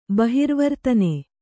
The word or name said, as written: ಬಹಿರ್ವರ್ತನೆ